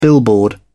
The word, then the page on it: billboard: British English